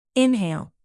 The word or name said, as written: inhale